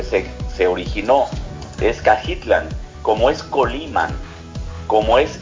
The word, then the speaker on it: Cajitlán: Sí, como es Tamalan, como es Tonayan, todos son nombres náhuatl que requieren esa pronunciación, okay. – Que es una pronunciación sin el acento. – Ok. – Sí, entonces Cajitlán.